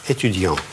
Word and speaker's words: étudiant: Если «студент», то конечная буква произносится в нос. Этюдиан.